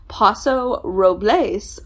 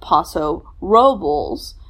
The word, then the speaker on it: Paso Robles: So he did some research on how to properly pronounce it, and there are two separate ways. Paso Robles, which is the Spanish pronunciation. However, that pronunciation is definitely a minority in the area. It's often pronounced Paso Robles by the locals. Just depends on the crowd.